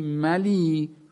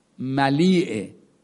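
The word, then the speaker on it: ملیء: ملی ملیعه، یه همزه هم آخرش هست که باید تلفظ بشه، اینجا به ضرورت شعری اون همزه رو دیگه تلفظ نمی کنیم.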